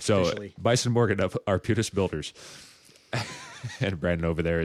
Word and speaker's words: Arbutus: Arbutus. That's the Latin name for Madrone, actually.